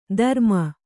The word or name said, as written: ಧರುಮ